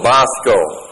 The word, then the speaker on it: bosko: B-O-S-K, long O, bosko.